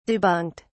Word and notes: debunked: British English

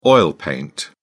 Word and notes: oil paint: British English

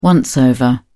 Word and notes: once-over: British English

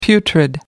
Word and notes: putrid: American English

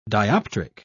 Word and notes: dioptric: British English

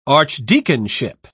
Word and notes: archdeaconship: British English

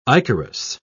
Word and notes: ichorous: British English

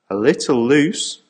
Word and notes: a little loose: American English

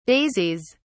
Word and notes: daisies: British English

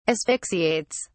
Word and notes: asphyxiates: British English

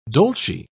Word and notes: dolce: British English

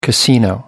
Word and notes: casino: American English

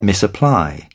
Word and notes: misapply: British English

